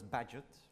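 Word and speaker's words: Bagehot: I almost wanted to pronounce the name of the column in The Economist, which I think should be pronounced as Badgett.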